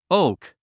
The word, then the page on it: oak: óuk; オーク